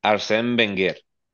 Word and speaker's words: Arsène Wenger: Eh, y, que, pareciera que con Arteta está, está llegando a esa, a, esa, a esa ola como la que hizo Arsene Werner. Werner, eh, nunca sé pronunciar su nombre. ¿Cómo lo pronuncias tú? Arsène Wenger.